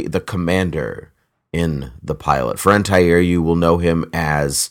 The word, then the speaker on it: Faran Tahir: Is it Faran Tahir? Is that's, that how you say his name? Yeah, that's how I've heard it pronounced.